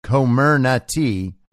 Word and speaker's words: Comirnaty: Pronounced phonetically as Comirnaty,